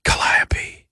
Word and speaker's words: Calliope: Now her own perfume as well, Calliope. Calliope. You got to say it like that.